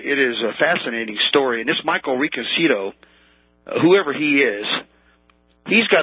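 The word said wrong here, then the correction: Riconosciuto: Yes. Reconosciuto is actually how it's pronounced.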